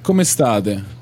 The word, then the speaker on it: come state: Come state? – Oh, it's kind of like Spanish. Okay, come state? Okay, so ciao, yeah, come state? How's my pronunciation? – Sì.